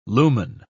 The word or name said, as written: lumen